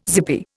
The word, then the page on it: zippy: Indian